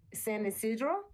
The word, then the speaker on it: San Isidro: But also what I took notice to when we were in San, how do you say that? – San Isidro. – San Isidro, mm-hmm.